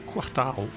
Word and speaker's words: Quartararo: Et en Moto 3, peut-être l'année prochaine avec ce jeune, très jeune là, hein, Quartararo qui arrive. – Quartararo. – Ouais. Enfin, ça, il va falloir prononcer Quartararo.